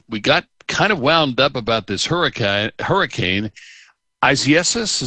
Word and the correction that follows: Isaias: Isaias. And I was just talking Isiasis, to Scott about this. Isaias. I don't think I'm putting the pronunciation in quite right when I say it, but that's how you say it.